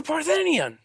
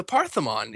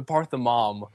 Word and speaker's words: Parthenon: And it says, yeah, then the Parthenon, you know, the Parthenom. You're just completely saying it wrong.